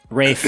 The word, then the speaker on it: Ralph: Ralph Fiennes villains for two. Rafe, I'm sorry. It's Rafe. That's how it's Fiennes? – Pronounced.